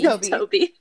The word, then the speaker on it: Tobe: I'm sorry, but is this dude's name Toby or Tobe? – It's Toby. – Toby. – Okay. Because I figured it was Toby, but I was like, it's spelled like Tobe.